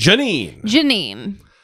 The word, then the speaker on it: Jeanine: Jeanine.